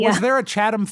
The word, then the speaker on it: Chatham: Some people would think that it might be pronounced Chatham. – Yes. Or Cheatham. – It's Chatham. – Chatham.